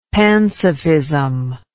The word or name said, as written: pansophism